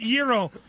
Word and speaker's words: gyro: Giggles goes, for your information, gyro is pronounced Euro. – Only in the commercials.